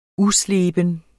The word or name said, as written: usleben